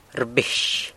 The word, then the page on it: rbix: sanlucasquiavini